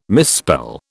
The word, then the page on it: misspell: British English